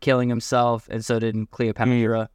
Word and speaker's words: Cleopatra: Cleopatra.